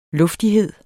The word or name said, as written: luftighed